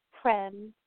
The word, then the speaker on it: Prem: Prem from Texas. – From Texas? And you said your name is Corinne? – Prem. – How do you spell it? – P-R-E-M.